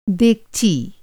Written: डेगची

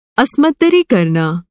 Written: अस्मतदरी

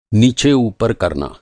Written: नीचे-ऊपर

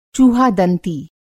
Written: चूहादंती

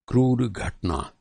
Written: क्रूर

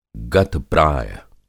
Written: गत-प्राय

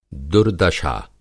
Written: दुर्दशा

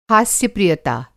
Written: हास्य-प्रियता

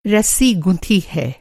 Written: गुथना

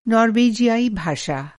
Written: नॉर्वेजियाई

